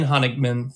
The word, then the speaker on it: Honigman: I serve as the co-founder and CEO of Red Partners, and I'm delighted to have with me today Brian Honigman for this episode of Pit Stops to Podium.